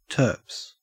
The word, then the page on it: turps: Australian English